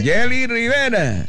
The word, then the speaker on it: Jerry Rivera: Pero los puertorriqueños dicen, aquí estamos con Jerry Rivera. – Ah, porque ellos no pronuncian la R sino la L. – Entonces, Jerry Rivera.